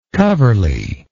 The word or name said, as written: coverley